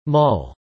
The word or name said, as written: mull